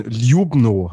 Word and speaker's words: Ljubno: Und genau, es steht jetzt am Wochenende in Ljubno.